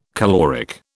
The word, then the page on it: caloric: British English